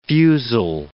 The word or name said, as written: fusile